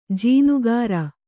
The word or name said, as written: ಜೀನಗಾರ